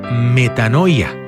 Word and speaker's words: metanoia: Esta palabra se pronuncia metanoia, que literalmente significa cambio de mente.